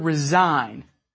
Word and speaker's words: resign: Now, how about the word resign? I want to resign from my job. Resign. R-E-S-I-G-N. What related word is the silent G sounded in? Well, when you resign, you must turn in your resignation. And let's think of 10 other words that are related in spelling and meaning to resign.